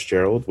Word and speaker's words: Gerald: If you don't know already, my name is Gerald with a G, not with a J.